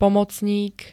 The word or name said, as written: pomocník